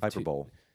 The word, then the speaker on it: hyperbole: Of course, I was being extreme to be sure for a point of emphasis, because the Bible does give hyperbole. – Hyperbole. Yeah, why is it? The English language is weird, because in this one, you're always told the E at the end of the word is silent, but not in hyperbole.